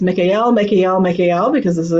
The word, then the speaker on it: Mikael: So this pronunciation is Mikael. And we hear that L sound vibration.